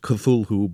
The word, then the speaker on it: Cthulhu: C-T-H-U-L-H-U. It looks as if it should be pronounced Cthulhu, but he maintained in a letter written much later that it's really two syllables, and the first one is sort of coughed or barked out.